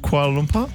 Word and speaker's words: Kuala Lumpur: Is it Kuala Lumpur? – Yeah, you said it right. – Kuala Lumpur.